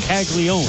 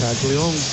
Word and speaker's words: Caglianone: It's a very interesting pronunciation of his last name when you consider having known Jack since he played at Florida. It's spelled C-A-G-L-I-A. N-O-N-E, like Caglianone, but it's just Caglione.